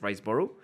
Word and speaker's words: Riseborough: Ahí me corrigen de la pronunciación, pero según yo es Riseborough.